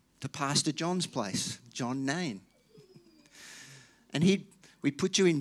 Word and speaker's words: Nain: When I first read it, I thought it was Nan, but it's actually pronounced Nain.